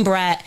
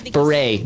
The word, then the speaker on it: beret: You, in this moment, have a retired Green Beret. – Yes, sorry. Also, probably on us. It's beret, not beret. Silent T. French, I believe.